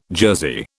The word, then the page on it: jersey: British English